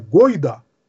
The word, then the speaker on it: гойда: При всей любви к татарам слово «гайда» с буквой «а» и другим ударением – это не их слово. То есть «гайда» с «а» их, а вот «гойда» – это русское слово, это древнее русское междометие, означающее призыв к действию.